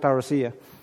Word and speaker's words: parousia: I guess I learned Greek, and I think we pronounce it parousia, which is the second coming, right?